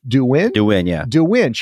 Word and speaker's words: Douyin: version of TikTok, and it's pronounced Douyin.